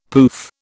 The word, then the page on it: poof: British English